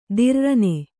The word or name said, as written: ತಿರ್ರನೆ